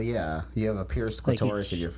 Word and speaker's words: clitoris: a pierced clitoris. Did I say that right? Clitoris? – Well, clitoris, that is correct.